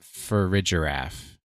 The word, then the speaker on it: Farigiraf: I would pronounce that Farigiraph. Like giraffe for rig, for rig giraffe.